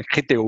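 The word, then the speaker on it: Criteo: Ninguém sabe como pronuncia bem esse nome, é Criteu, Critel, tem várias pronúncias, mas enfim, eu falo Critel bem abrasileirado.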